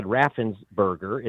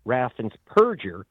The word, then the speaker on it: Raffensperger: So does his Secretary of State, whose name is mispronounced as Brad Raffensperger. It's Raffensperger. He is the purge in general of Georgia.